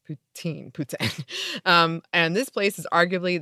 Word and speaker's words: poutine: First, in French, or at least Quebec French, the word poutine is pronounced the same as the ruler poutin.